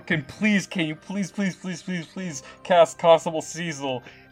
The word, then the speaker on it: Cesyll: It's spelled C-E-S-Y-L-L, but it's 100% pronounced Cecil.